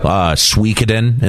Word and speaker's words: Suikoden: Suikoden is one that I mean, is that how you say it? – Yes.